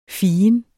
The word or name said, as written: ficus